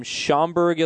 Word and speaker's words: Schaumburg: Brandon from Schaumburg, Illinois. – Mm-hmm. – Did I pronounce that right? – Chicago suburb. Nice job.